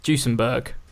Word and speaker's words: Duesenberg: I just didn't know how you pronounce it. Duesenberg. Duesenberg. Right.